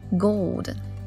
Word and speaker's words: gold: 另外要注意 gold 这个词里要把 l 发出来 gold。